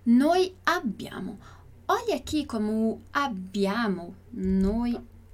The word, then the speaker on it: abbiamo: Mas tem os dois Bs, então atenção, attenzione alla pronuncia. Então, o sonzinho demorado. Abbiamo, abbiamo.